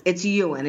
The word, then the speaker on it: Ewan: Is that how you say his name, Ewan or Ewan, or how do you say it? Ewan. It's Ewan. It is kind of Scottish, like Ewan McGregor, the actor.